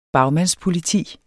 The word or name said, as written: bagmandspoliti